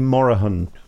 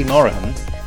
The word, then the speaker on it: Morahan: She actually pronounces her name Morahan. – Is it Morahan? Oh, is it? Oh, okay. – And she'd been saying, don't worry, she'd been calling herself Hattie Morahan for years and years, and then her father said to her, you do realise you've been mispronouncing your surname.